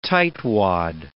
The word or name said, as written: tightwad